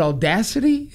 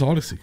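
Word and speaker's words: Audacy: Is it Audacity? Is that how you say it? – I think it's Odyssey.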